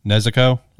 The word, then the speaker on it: Nezuko: I'm drawing a blank on her name, how to pronounce it. Nezuko? – Yes.